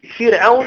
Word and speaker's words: فرعون: يعني هذه الكسرة، هذه الكسرة أصلية في الكلمة، أصلية في الكلمة، هذه الكسرة في فرعون، هذه الكسرة أصلية في الكلمة. هل سمعتم كلمة فرعون تنطق فرعون أو فرعون؟ هي دائما فرعون، إذن هي أصلية، لذلك ترقق، ترقق الراء.